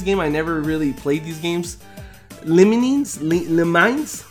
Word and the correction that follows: Lemmings: Lemines, Lemmings. – Really? – Lemmings.